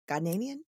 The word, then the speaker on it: Ghanaian: Is that how you say it? Ghanaian? From Ghana? – Ghanaian.